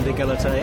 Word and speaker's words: égalité: Yeah, it does fit in with the French ideals of fraternité and égalité. Absolutely. Yes. – Well, we're going to have a bit of fraternité and some égalité. How do you say it? – Égalité. – Égalité.